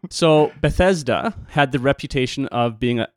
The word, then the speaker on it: Bethesda: This particular pool then, it's in a town called, how do you pronounce this town? – I say Bethesda. – Bethesda.